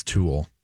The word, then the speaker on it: toile: By the way, I can confirm it's pronounced as Tool. – Oh, it is Tool. – Yeah. – Thank you for calling us. Not Tool, T-U-L-L-E, but T-O-I-L-E. They're both pronounced the same.